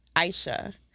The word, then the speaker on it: Isha: And how do you say it? – Aisha. – Aisha. – Yes. – Like ISIS, but Aisha.